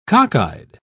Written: cockeyed

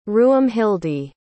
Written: ruomhildi

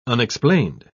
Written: unexplained

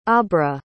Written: aubra

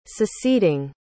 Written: seceding

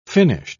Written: finished

